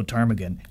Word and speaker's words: ptarmigan: Ptarmigan. How is that? What's the matter? Oh, I forgot a P. The P is silent. Ah, I see. Ptarmigan. Well, you're welcome. Now, Willow Ptarmigan, you live in Alaska.